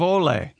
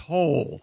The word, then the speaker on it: Hole: Ole. – Yeah, Ole. – But it's pronounced Hole. – Yeah. – Hole? – Detective A. Hole. Yeah. – It's spelled H-O-L-E.